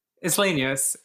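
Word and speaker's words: Isleños: So some Filipino refugees, Native Americans, and the Los Islanos, is how you say it, right? – Islanos. – Uh-huh. Islanos, sorry. I'm mispronouncing that word.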